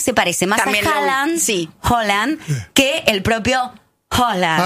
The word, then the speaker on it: Haaland: Que en noruego se pronuncia Haaland.